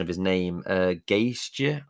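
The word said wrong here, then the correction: Gajser: or whatever his name was, the Slovenian. – Tim Geiser. Yes, he won that MX.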